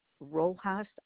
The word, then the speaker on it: Rojas: Rojas, R-O-J-A-S.